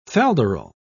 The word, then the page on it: falderal: British English